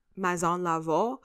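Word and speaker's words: Maison Lavaux: I also want to let you guys know that I just butchered a couple moments ago. It's Savoir is the PR firm and Maison Laveau is Antoine's company.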